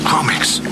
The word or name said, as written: Kromaggs